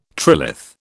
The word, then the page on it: trilith: British English